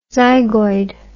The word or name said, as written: zygoid